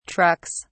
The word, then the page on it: trucks: British English